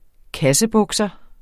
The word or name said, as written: kassebukser